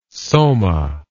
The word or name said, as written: soma